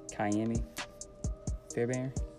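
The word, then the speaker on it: Ka'imi Fairbairn: Oh, and the best kicker, Kyami Fairbairn. That's how you say his name.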